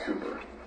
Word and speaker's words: Cowper: By William, it's spelled Cowper, but it's pronounced Cooper.